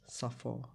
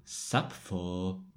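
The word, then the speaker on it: Sapfó: Nebylo to nic zvláštního potom, kdybychom se dostali třeba antický Řecko, Aristoteles, Platon a Safo. – Sapfo.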